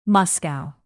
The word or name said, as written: moscow